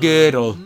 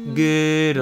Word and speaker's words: Gödel: Also, like, do you know Gödel's incompleteness theorem? I'm saying it wrong. Gödel. Gödel. – No, how does it go? – G-O with an umlaut. D-E-L.